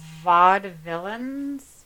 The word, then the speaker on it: vaudevillians: Her parents were vaudevillians.